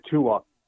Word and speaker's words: Tua: So I just got out of work, so I finally got a chance to see the hit tonight on Tua.